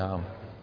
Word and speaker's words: عمرو: محمد عمرو الشماع، يلا يا عمرو. عمر تكتب بالواو ولكن هذه الواو لا تلفظ، انتبهتوا؟ عمر، جاء عمر، رأيت عمرا، مررت بعمر، فلا يقال عمرو، في كتير بيقول لك عمرو. الواو هذه تكتب ولا تلفظ.